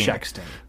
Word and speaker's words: shexting: S-H-E-X-T-I-N-G. Shexting. – Wow. I have no idea. It sounds like sexting, but it's like shexting.